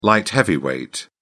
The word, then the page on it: light heavyweight: British English